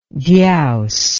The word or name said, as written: dyaus